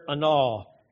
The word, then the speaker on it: anah: Here in these verses, the word afflict in the Hebrew is the word anah. It's A-N-A-H is the way it's spelled.